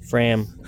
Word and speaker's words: Fram: It's called the Race to the Poles, and celebrates the polar exploration vessel Farm. There's your shout-out. – Fram. – But you know what? It's a extra special Jeff promo when it's mispronounced.